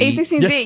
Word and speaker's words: a16z: A lot of people do say A16, but it's A16Z. – Yeah. – So, but you were doing it A16Z and Z. You added an and Z.